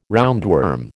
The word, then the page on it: roundworm: American English